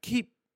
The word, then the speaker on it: Kiwior: Kiwior, I think, is his. – Of course, he said it right. – You said it right. – Kiwior. – The W turns to a B.